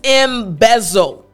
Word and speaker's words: embezzle: Before I show you an example sentence, I want to go over the pronunciation because the z sound can be just a bit tricky. So again, the word is embezzle.